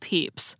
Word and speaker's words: Pepys: But his name is actually pronounced Pepys, like the marshmallow chicks you buy for Easter.